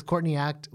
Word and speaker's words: Courtney Act: Because Courtney Act, when you say it in an Australian accent, it sounds like caught in the act.